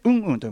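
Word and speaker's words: UM-HUM: うんうんこれね綴りがね UM、えハイフン HUM でうんうんと読むうんうん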